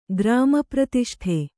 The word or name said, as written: ಗ್ರಾಮಪ್ರತಿಷ್ಠೆ